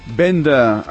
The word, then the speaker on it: venda: Venda, exacte. Amb D i no vas amb T. És així com ho hem de pronunciar i escriure.